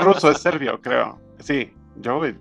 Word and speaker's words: Djokovic: Djokovic, ¿cómo se pronuncia? Tú se sabías a saber. – Djokovic. – Djokovic.